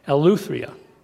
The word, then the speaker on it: Eleutheria: The word freedom or liberty is used in other translations, and it's pronounced Eleutheria.